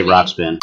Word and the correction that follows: Ruxpin: Can you pronounce that again? – Teddy Ruxpin. – Okay. You got it right that time.